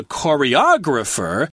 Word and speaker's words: choreograph: Choreograph as a verb has the accent on the first syllable, choreograph, whereas the person is choreographer, the accent is a little later in the word.